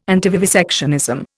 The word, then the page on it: antivivisectionism: Indian